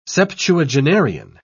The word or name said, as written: septuagenarian